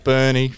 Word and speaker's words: Burnie: It is pronounced Burnie, right? – Yep.